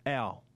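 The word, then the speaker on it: Hough: We've been told it's pronounced Ow. As in, ow, when you cop a blow to the arm or something. Ow! That's how you pronounce it. But it would appear as though it reads like off. – O-U-G-H.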